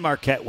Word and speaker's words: Marquette: I have seen, I think ESPN's has Marquette against Colgate, and then if they win, Marquette wins.